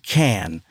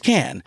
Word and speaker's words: Cannes: When I was in Cannes for, and it is pronounced Cannes. – Yes. – I was like, Cannes. No, it's Cannes.